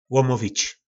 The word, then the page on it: lomovic: po nasimu